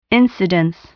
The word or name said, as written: incidence